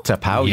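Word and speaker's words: T'Pau: Altered Images, and am I saying this right? Tapau? – Tapau, yes.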